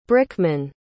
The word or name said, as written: brickman